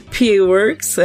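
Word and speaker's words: P.A. Works: Esse é o um novo anime original do Pia Works. Será que falei certo? Vamos descobrir. – Pia Works, é.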